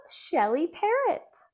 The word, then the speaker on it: Shelly Perret: I want to introduce the beautiful Shelly Perret.